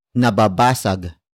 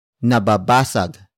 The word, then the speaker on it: nababasag: The next word is nababasag. Nababasag. Nababasag. Nababasag is spelled as N-A-B-A-B-A-S-A-G.